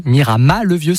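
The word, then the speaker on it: Miramas-le-Vieux: Ah, moi, ce que j'adore, c'est que la commune, on prononce Miramas, mais pour le village perché, on dit Miramas le Vieux sans le S.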